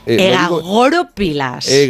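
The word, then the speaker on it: egagrópilas: Egagoropilas. – Vamos a poner el acento en la O.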